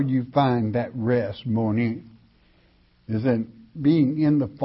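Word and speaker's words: moni (μονή): The word is M-O-N-E in the Greek, moni, or mone, if you wish to pronounce it that way.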